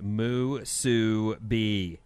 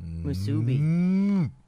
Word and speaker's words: musubi: You're saying it wrong. It's pronounced moo-soo-bee. – Musubi.